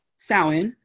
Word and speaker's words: Samhain: So Halloween, originally known as Samhain.